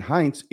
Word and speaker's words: Hintze: Pennsylvania State Police have charged Rhett Hintze, that's how I'm going to pronounce it, H-I-N-T-Z-E. The Rhett, I think, is like Rhett Butler, R-H-E-T-T.